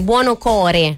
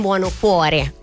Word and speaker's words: Buonocore: Ho sempre paura di sbagliare il cognome, l'ho detto bene. Buonocore, non Buonocuore.